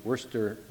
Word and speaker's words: Worcester: She then was called to share the office of pastor and is co-pastor at Trinity Evangelical Lutheran Church in Worcester, Massachusetts.